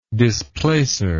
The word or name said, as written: displacer